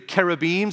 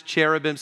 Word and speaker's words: cherubim: And by the way, some people ask me, hold on, uh, are you pronouncing that that way? Well, that's the way that it is pronounced in Hebrew, the cherubim.